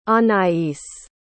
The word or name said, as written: anais